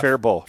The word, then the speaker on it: Faribault: Lightning strikes the Faribault. – Gas? – Am I pronouncing that right? – Yeah. – Faribault. – Faribault. – Faribault. – Like, Faribault. – Yes. It's like my cousin Bo.